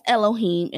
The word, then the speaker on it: Elohim: So that's Elohim, and that's spelled E L O H I M.